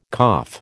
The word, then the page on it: cough: American English